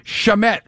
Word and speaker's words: Shamet: First things first, a guy, you know, how many guys had Landry Shamet, rhymes with Damet, for the game time basket?